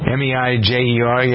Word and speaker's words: Meijer: May, is it Major Meyer? How do you pronounce that? I don't know that store chain. – Don't know it. – Meijer.